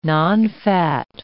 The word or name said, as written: nonfat